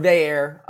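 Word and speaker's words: Veilleux: It's Christian Veyer or Veyer. I think it's just Veyer.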